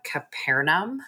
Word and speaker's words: Capernaum: It's called Capernaum, I believe is how you pronounce it.